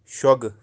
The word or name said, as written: shogu